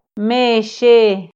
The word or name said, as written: mexe